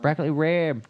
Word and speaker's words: broccoli rabe: Broccoli Rabe? Is that how you say it normally? – Broccoli Rabe. No, it's just called Broccoli Rabe, believe it or not.